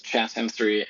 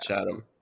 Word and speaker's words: Chatham: Chatham. – Okay. – Yeah. – You said Chatham. I kind of turn it into a little Chatham sometimes. – Yeah, it's just one syllable. Well, it's two syllables.